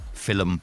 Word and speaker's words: film: F-I-L-L-U-M. Film. – Film. Yeah, I saw a great film last night. – Mm. – I don't know where. – An extra syllable there. Just film.